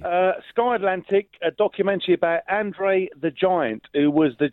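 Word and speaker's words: André: His name's pronounced Andre. It's not Andre. Andrex. Andrex. Andrex the toilet roll. Yeah, Andre.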